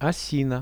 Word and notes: osina: Russian

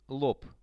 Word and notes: lob: Russian

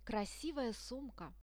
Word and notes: krasivaya sumka: Russian

